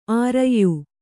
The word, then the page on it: ಆರಯ್ಯು: Kannada